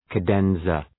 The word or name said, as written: cadenza